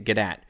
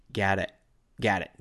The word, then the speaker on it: Gadot: Gadot. Gadot. G-A-D-O-T. Gadot. She's not French. She's Israeli, so you say the T.